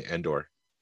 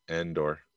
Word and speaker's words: Andor: And if you put the wrong inflection on it, you're going to say Endor. – Yeah. – It's like a short A, Andor. Andor.